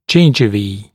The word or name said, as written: gingivae